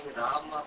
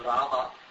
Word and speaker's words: Rama: I was pronouncing Rama as Ram. – One syllable instead of two. – Yeah, instead of Rama.